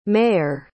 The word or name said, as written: mayur